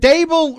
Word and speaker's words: Dable: Is it Dable rhymes with table? – Yes. That's what he said.